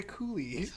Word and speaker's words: coulis: It's topped with a swirl of raspberry coulis. – Oh, I'm so is that nervous. How I'm pronouncing that right? Yeah, you are pronouncing that correct, but that should not go on top of ketchup.